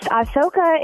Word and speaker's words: Ahsoka: Yes, Ahsoka is one that Robert picked.